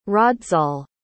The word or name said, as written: raadzaal